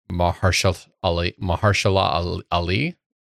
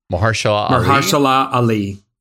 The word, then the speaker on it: Mahershala Ali: which is starring Mahershala Ali. I butchered that so bad. So badly. – Mahershala Ali. Yeah.